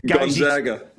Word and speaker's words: Gonzaga: Gonzaga.